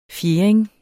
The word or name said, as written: fjerding